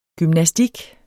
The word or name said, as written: gymnastik